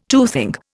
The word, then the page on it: toothing: Indian